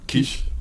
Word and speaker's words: Quiche: War, gab auf jeden Fall gut, gute, guten äh, Quiche, wird das so ausgesprochen? Quiche? Quiche? – Ja, ja Quiche.